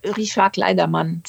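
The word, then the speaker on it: Richard Clayderman: Wie wird er ausgesprochen? Kollegen sagen manchmal Richard Kleidermann, aber Richard Gleidermann oder wie sagst du? – Richard Kleidermann, sag ich.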